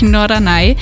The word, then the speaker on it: Norderney: Er meint natürlich Norderney.